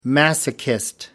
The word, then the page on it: masochist: British English